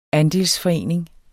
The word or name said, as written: andelsforening